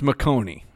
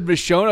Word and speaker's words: Michonne: Like, because on the show, Rick ended up with Michonne. – No, but in the comics, comic, Andrea. – So you're telling me. – And she's an awesome sniper. – It's not pronounced McConey.